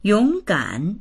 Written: yong3gan3

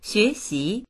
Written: xue2xi2

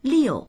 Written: liu4

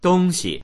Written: dong1xi

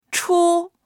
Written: chu1

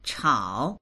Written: chao3

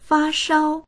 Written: fa1shao1

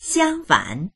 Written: xiang1fan3

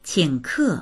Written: qing3ke4

